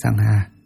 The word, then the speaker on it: Sangha: Tăng nguyên là tăng giả, tiếng Ấn Độ đọc là Sangha, dịch nghĩa ra chữ Hán là hòa hợp chúng.